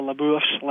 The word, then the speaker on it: LaBeouf: Gosh, Gary Oldman, Guy Pearce, and I can never remember how to pronounce Shia LaBeouf's last name.